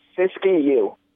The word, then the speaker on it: Siskiyou: Is it Siskiyou? Is that how they pronounce that county? – Siskiyou.